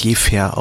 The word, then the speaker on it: Jever: Also man spricht Jever eigentlich als Jever aus.